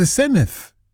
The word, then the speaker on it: Senedd: But unfortunately, a lot of people on the news can't seem to gist the fact that it's the Senedd with a th, not Senedd. With a hard D. – It's not a Senedd. It's the Senedd. – Yeah, exactly. – I thought you said it's pronounced just as it's written. – But it's the double D. Yeah, so that creates a th sound.